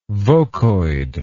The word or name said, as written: vocoid